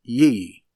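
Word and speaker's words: ye (γῆ): And the word translated earth, the Greek is ye, written G-E, pronounced ye, offers us no specificity.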